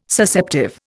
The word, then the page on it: Susceptive: Indian